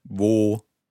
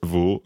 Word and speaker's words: wo: And then W, which is V, and W is pronounced as a V sound in English. So we've already come across... – Wo, wer, was.